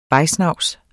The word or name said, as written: vejsnavs